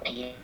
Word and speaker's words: Pierre: So Pierre is the French spelling of Peter. So French people call it Pierre.